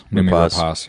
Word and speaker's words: Noomi Rapace: Noomi Rapace, yeah.